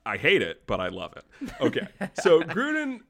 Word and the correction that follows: Gruden: how do you say it? – John Gruden, the football coach of the Las Vegas Raiders.